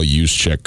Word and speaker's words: Juszczyk: So you're just going to be like the keeper of accuracy, and you're just going to interrupt us to make sure that we've got Kyle Juszczyk correct and so forth and so on as we go? – That, yeah. – Well done with the Juszczyk pronunciation, Gus.